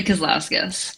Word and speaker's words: Kazlauskas: Did I say it right? – Actually, Kazlauskas.